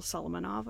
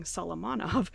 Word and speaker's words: Solomonov: So that is Zahav by Michael Solomonov. I'm saying that wrong. Solomonov and Stephen Cook.